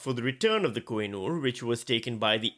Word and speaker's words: Koh-i-Noor: Koh-i-Noor, I think, is the correct pronunciation. – It's the Koh-i-Noor.